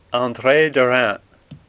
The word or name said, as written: Derain